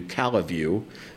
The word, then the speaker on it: Calle: or i guess i should pronounce it the way people in la grange do calaview um, but if you grew up in california as i did you know c-a-l-l-e is calle or street um, and it's I, I, yeah, so it was sort of a larger piece of property.